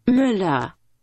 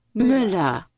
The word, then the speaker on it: Müller: If you go to the internet to try and figure out how to say this, the M-U-E-L-L-E-R, this is what it says. Here we go. – Müller. – Okay. – Müller.